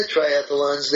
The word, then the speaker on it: triathlon: Okay, so I discovered that in the triathlons, they were offering The first thing you have to know about triathlon, because this is near and dear to my heart, it's 3 syllables, not 4.